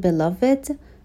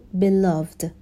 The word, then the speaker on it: beloved: خب اول این رو اشاره بکنم که کلمه beloved به معنای محبوب هستش و دو تا تلفظ میتونه داشته باشه. هم بگیم beloved و هم بگیم beloved. هر دوتا درست هستش.